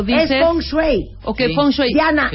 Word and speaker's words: feng shui: Que es la primera vez que escucha en un medio a alguien pronunciar la palabra Feng Shui correctamente.